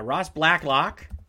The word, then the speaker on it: Blacklock: Yeah, Ross Blacklock, Rager's teammate at TCU.